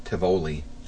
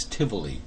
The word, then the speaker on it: Tivoli: Tivoli. See, I say it wrong. – It's Tivoli. – Tivoli. But see, when I look at the word, I can't say it. – Well, you know how I know how it's pronounced is. Basically, it's the name of the amusement park. It's right in the middle of Copenhagen, Denmark, which is Tivoli Gardens.